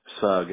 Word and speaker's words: Sugg: Sugg, S-U-G-G.